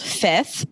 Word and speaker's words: Fvith: I literally recently, sorry to go off track, but I recently bought a candle from a company called Fifth, but it's spelled like F-V-I-T-H.